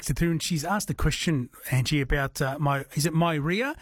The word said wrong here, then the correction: Meyer: She says, does the iron issue apply to myria as well? – So maybe a Meyer lemon, so M-E-Y-E-R. – Yeah. That's it, yes. Excuse my bad pronunciation of that.